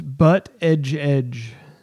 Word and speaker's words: Buttigieg: It's pronounced okay. Butt Edge Edge. And he actually has a shirt that says butt-edge-edge.